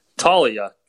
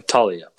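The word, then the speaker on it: Taulia: Talia. That's how you pronounce his name. Talia.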